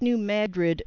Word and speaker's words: New Madrid: And it is pronounced New Madrid after the town in the state of Missouri, New Madrid, Missouri. – New Madrid, Missouri, that's correct. – Right, not New Madrid, as some people want to call it in the national media.